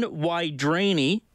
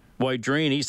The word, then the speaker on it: Wydareny: So John, common spelling, W-Y-D-A-R-E-N-Y, but people saying it's pronounced Wydrani, born in 1941.